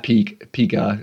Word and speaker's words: pica: It's spelled P-I-C-A, and I hear a lot of psychological novices call it pica, sort of like Pikachu, the Pokemon, but it's not pica. It's not like Pikachu, and it's not like, I don't know how you pronounce the font, P-I-C-A, but this is pronounced pica.